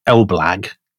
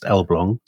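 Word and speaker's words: Elbląg: It's spelt Elblag, but it's pronounced Elblong. So my Polish contacts tell me.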